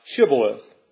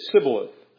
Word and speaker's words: Shibboleth: If he said, Nay, then they said unto him, Say now, Shibboleth. And he said, Sibboleth, for he could not pronounce so pronounce.